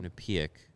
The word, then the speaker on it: Quinnipiac: Quinnipiac.